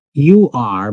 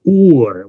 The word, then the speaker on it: Ur: U-R, but pronounced Ur with a double O sound, Ur.